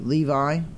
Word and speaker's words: Levi: What if I said Levy? Levi. Levi. L-E-V-I. Levi. I'm trying to remember. Yeah. Well, Levy is, I think, L-E-V-Y. That's why I didn't want to say it wrong.